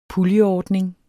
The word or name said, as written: puljeordning